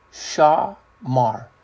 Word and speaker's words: shamar: The word kind of similar, it's pronounced shamar, 8104 in the Hebrew.